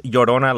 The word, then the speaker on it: Llorona: The other creature here, not a creature, but like another thing, is La Llorona, which is pronounced Llorona, like yo.